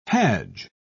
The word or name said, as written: hajj